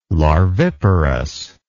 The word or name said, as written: larviparous